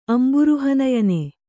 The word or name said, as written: ಅಂಬುರುಹನಯನ